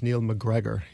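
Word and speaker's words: Neil MacGregor: It's Neil McGregor.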